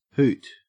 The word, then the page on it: hoot: Australian English